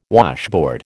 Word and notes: washboard: American English

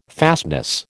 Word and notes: fastness: American English

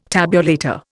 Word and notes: tabulator: Indian

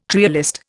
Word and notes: trialist: Indian

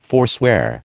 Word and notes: forswear: American English

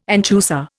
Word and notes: anchusa: Indian

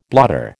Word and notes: blotter: American English

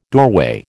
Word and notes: doorway: American English

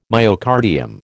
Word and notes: myocardium: American English

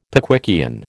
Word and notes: Pickwickian: American English

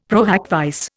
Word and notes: pro hac vice: Indian

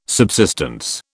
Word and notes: subsistence: British English

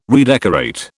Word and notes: redecorate: British English

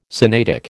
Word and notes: Sinaitic: American English

